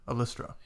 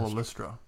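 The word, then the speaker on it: Ollistra: But I most notably remember her as Orlistra. No. How do you say it? – Alistra. – Yeah. Cardinal Alistra from the War Doctor box sets.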